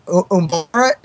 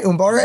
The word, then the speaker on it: Umbara: And the last one, the Umbara, am I saying it right? Umbara episodes? – Yes.